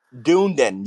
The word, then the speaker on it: Dunedin: Dunedin. Yeah, it's actually a suburb. – Never even heard of it. – It's a suburb of Clearwater. It's called Dunedin.